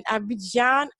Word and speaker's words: Abidjan: So we have author that was born in Abidjan, Ivory Coast. I hope I said that right. E.B. Kevin, welcome to the show. – Yes, that's correct. Thank you, for having me on your show.